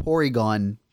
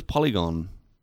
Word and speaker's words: Porygon: As if you wouldn't be like, I mean, I caught a Porygon. – Dude, that is amazing. – Yeah. – Is that how you pronounce it? I thought it was Polygon. – There's an R.